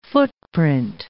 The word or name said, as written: footprint